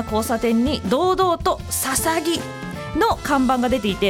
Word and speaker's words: 大角豆: ビーンの豆と書いてささぎです。大きな角の豆と書くので初見では大角図や大角豆、大角豆としか読めないのですが、なんとこれでささぎと読むのです。